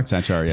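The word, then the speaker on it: Sachar: I don't know how to pronounce Sakhar? – His name. Sakhar? Sakhar. – Sakhar, yeah.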